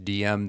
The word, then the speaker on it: DIAM: D-I-A-M, it's pronounced DM.